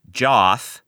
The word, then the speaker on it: Joth: Joth. And I'm not pronouncing that incorrectly. It's J-O-T-H.